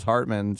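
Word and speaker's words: Heartman: This is Hartman, spelled like Hartman, not like H-R-T, like the deer. H-E-A-R-T, like the organ.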